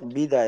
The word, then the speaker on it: بدأ: تنطق بدا بكسر الباء ايوه بدا بس نعم.